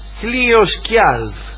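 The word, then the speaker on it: Hlidskjalf: Como reina de los dioses, solo ella tenía el privilegio de sentarse sobre el trono Hlioskjalf, o yo lo pronunciaría Hlioskjalf, porque la J por ahí va como I, Hlioskjalf, cuyo dueño era Odín.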